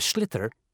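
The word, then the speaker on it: sliotar: This is what it looks like, and basically you have to get this ball, called a slither, that's S-L-I-O-T-A-R, so it's not spelt the way it's pronounced.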